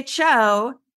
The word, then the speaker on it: Tcho: And when I say Cho, it sounds like it's spelled C-H-O. Mm-hmm. And it's a chocolate company. – Yeah. – And if I was talking to somebody coming to the Bay Area where they're located, and I said, and they're like, oh, I love chocolate. I said, oh, you have to go check out the Cho factory. You would have no idea that it was spelled T-C-H-O. – Yes. – T-C-H-O. Yes. So T is silent.